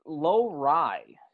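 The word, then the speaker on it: Lohrei: It's Lowry. So Lowry, like low-rise jeans, baby.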